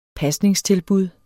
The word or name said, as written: pasningstilbud